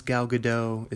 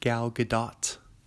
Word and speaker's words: Gal Gadot: It's not pronounced Gal Gadot. It's pronounced Gal Gadot.